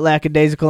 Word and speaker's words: lackadaisical: And it's not z either. – Yeah, there's no z. It's like daisy. Lackadaisical.